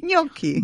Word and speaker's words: gnocchi: Un'altra parola che non tutti riescono a pronunciare bene, gnocchi. – Gnocchi, gnocco, gnocca, gna. La parola gna. – Non sono capace a dirla. – Non sono capace a dire loro. – Noi non abbiamo problemi. – No. Dillo un po', gnocchi. – Gnocchi. – Brava, hai detto bene.